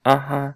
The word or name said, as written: aha